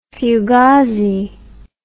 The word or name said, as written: fugazi